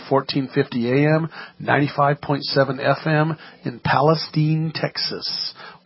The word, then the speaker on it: Palestine: Yeah, Palestine's Steen. A traditional pronunciation. Yeah. – See, my producer told me, make sure you say Steen. – I did. I sent him an email. So remember, this is Steen.